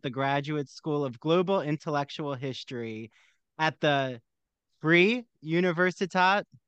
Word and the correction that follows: Freie Universität: Freie Universität, but yeah, not bad.